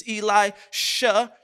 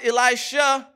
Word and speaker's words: Elisha: Let's make sure we overemphasize the pronunciation there. Elijah is who we are talking about. This is Elisha. You shall anoint Elisha as prophet in your place.